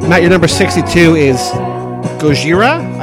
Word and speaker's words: Gojira: Yes, Gojira is how you pronounce it. – Like, that's Japanese. – That's what Godzilla is. Godzilla is our pronunciation. Gojira.